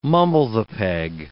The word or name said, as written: mumble-the-peg